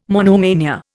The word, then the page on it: monomania: Indian